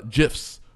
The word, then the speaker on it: GIF: Not GIF, but it's GIF, like the peanut butter. – Like the peanut butter. It's where they're repeating GIFs over and over.